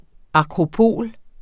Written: akropol

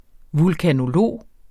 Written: vulkanolog

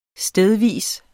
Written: stedvis

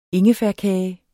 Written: ingefærkage

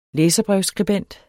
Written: læserbrevsskribent

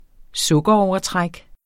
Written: sukkerovertræk

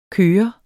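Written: køre

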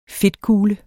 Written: fedtkugle

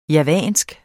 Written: JAVANSK

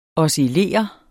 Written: OSCILLERE

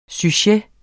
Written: sujet

